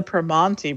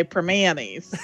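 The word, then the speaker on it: Primanti: and they always pronounce it the Permanente Brothers sandwich. Like, they make it sound like so formal and frou-frou. And let me tell you, in Pittsburgh, we have a heck of an accent and we always say Permanis. So that's how it's said, Permanis.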